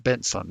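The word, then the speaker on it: Bentsen: I believe it's pronounced Benson, though, without the T.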